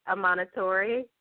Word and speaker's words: Amanitore: You're close. It's Amanitore.